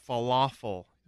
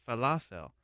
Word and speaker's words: falafel: It's not falafel, U-L. It's spelled E-L. It's falafel.